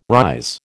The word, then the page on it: rise: American English